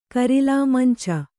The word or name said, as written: ಕರಿಲಾಮಂಚ